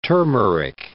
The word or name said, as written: turmeric